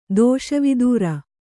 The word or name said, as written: ದೋಷವಿದೂರ